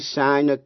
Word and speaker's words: sign: Âm G trong chữ sign câm, nhưng phát âm trong chữ signature, danh từ. Trong chữ sign, S-I-G-N, nghĩa là ký tên, âm G câm, không phát âm.